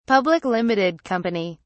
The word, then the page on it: Public Limited Company: British English